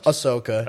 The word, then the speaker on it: Ahsoka: Ahsoka.